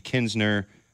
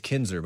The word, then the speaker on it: Kinser: It's Kinsner, by the way, not Kinsner. – I was reading fast. – Did he misspell it? – No. K-I-N-S-E-R.